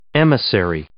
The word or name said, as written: emissary